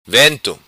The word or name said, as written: Vento